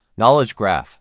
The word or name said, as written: Knowledge graph